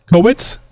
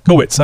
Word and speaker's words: Kowitz: But my goodness, Brad, you'll have to correct me on this. K-O-W-I-T-Z. Kowitz? Kowitz? Kowitz? Kowitz. I bet you it's Kowitz. – I'd go with that.